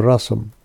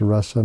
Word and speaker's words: rasa: By the word, it's a short A, it's rasa, not rasa. The dance is a rasa dance, but the juice is a rasa, is rasa.